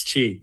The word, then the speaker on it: Qi: But, and also pronouncing it because it's spelled Qi. So it's like, right. Everyone says Qi, yeah. And it's like, that's Qi.